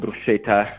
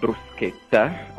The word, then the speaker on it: bruschetta: Okay, so that's the correct Italian pronunciation. Not bruschetta, but bruschetta.